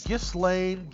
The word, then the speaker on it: Ghislaine: So, Ghislaine, Ghislaine. I never had a Maxwell. How do you say it? Ghislaine? – I think you don't say the G.